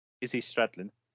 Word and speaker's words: Izzy Stradlin: Is it Izzy Stradlin, isn't it? Is that how you say his name? – Izzy Stradlin. – Is it? Is that how you say his name? – Yeah, sounds like it.